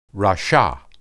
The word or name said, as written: rasha